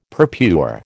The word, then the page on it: purpure: American English